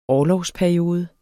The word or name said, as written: orlovsperiode